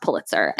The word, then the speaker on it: Pulitzer: Also thrilled to know after reading this book, because there is a Pulitzer winning author in this book, that according to Andrew Sean Greer, it is pronounced Pulitzer.